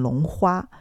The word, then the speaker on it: 龙华: 但后来啊，我又发现其实我奶奶没有念错，这个“ 龙华” 这两个字的确可以念成“ 龙花”，因为“ 华” 也通“ 花”，那这个“ 花” 其实是花朵的“ 花”。